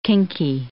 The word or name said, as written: kinky